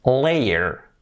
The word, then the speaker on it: layer: Por lo tanto, layer se pronunciaría layer, layer, layer.